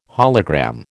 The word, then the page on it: hologram: American English